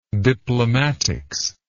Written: diplomatics

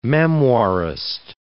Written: memoirist

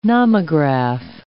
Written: nomograph

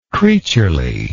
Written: creatureliness

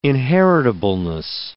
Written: inheritableness

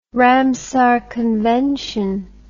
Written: Ramsar Convention

